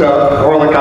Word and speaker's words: Horlick: It's H-O-R-L-I-C-K.